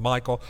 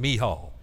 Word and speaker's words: Michal: I'm using the Hebrew pronunciation. I know all the rest of you going, "Michael, why is he saying Michal?"